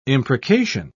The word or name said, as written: imprecation